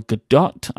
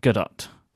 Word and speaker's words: Gadot: But the actress who plays Wonder Woman, her name is pronounced Gail Gadot, I assume. It's because we were telling people that we need to, people have been telling us we need to pronounce the T.